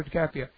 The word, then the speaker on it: DiCaprio: And it was really interesting movie because how do you pronounce his name, the young actor, DiCaprio? Yeah, Leonardo DiCaprio.